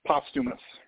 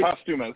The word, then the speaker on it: Posthumus: Posthumus. – Posthumus. Posthumus, right? – Posthumus, yep.